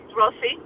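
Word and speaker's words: Rosy: Now, is it Rosie? Am I saying that correctly? – Uh-huh. It's R-O-S-Y. Rosie Dana.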